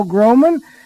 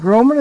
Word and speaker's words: Groman: My name is Paul Groman, and Groman is spelled G-R-O-M-A-N. G-R-O-M-A-N, that's Day of Decision.